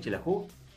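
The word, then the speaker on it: Xelajú: ¿Lo dije bien, Chelajú? – Sí.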